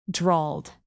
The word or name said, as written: drawled